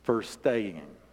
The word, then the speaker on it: verstehen: Fur, like if you are staying. Like we don't use the word G in Tennessee on the end of anything, like staying. So it's just like you always say staying anyway, so it's furstaying. But it's actually spelled with a V. It's a German word. It's spelled V-E-R-S-T-E-H-E-N.